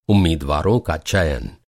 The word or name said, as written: चयन